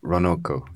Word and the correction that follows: ronroco: Ronroco. – Trondoroco. Is that better? Trondoroco. – No, it's not Tron. It's kind of like Ron Howard, like Ron Roco.